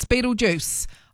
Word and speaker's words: Betelgeuse: And my son told me it's Betelgeuse.